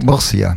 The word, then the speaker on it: Murcia: Murcia, stimmt? – Murcia.